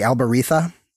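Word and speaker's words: albariza: When I'm studying, like, Jerez and the soils around Jerez, the big important one to remember is the Albarita, spelled with a Z but pronounced with a lisp, because, you know, Spanish.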